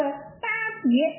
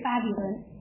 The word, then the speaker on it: 巴别: 这个巴别就是巴比伦，你发音试试，巴比伦啊，那个巴别，它的音是一个啊。